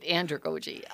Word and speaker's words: andragogy: And in your paper, you had a section on how adults learn, and you used the word andragogy. What's that? Did I pronounce it correctly? – You did, andragogy.